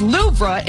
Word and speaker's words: Louvre: The weight loss drug is high on the list, along with Storm Eowyn and the Louvre Museum, which is pronounced Louvre in France.